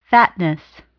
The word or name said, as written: fatness